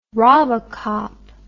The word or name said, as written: robocop